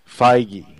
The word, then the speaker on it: Feige: And his name is pronounced Feige.